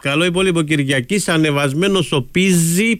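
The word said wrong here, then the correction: Pizzi: Πίτσι το λέει ο Πίζη, έτσι μας είπαν ότι προφέρεται το όνομά του,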